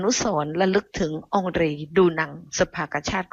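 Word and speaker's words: Henri Dunant: เมื่อกี้ที่ฉันอ่านคำว่าองรีดูนังคนผักดันน่ะชื่อองรีดูนังแต่ถนนในบ้านเราเนี่ยอ่านว่าอังรีดูนังนะคะต่อมานะคะพอมีถนนสมรามาเนี่ยนะคะในปีพ.ศ.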